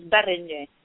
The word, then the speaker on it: Berenyi: If you're Hungarian, the enunciation is Berenyi.